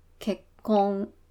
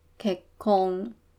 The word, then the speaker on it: 結婚: 那结婚这个呢，它的汉字一样是写作结婚，那它日文发音呢，念作結婚，結婚。要注意，它中间有一个小的促音，就是 k 跟空间有一个促音，所以不是 k 空，是 k 空。